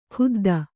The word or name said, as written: ಖುದ್ದ